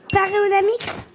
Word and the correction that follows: Panoramix: Ouais, oh, il y a une petite inversion de syllabe, mais peut-être que Paul va venir à. Euh, Panoramix C'est ça, c'était ça. Voilà. Panoramix.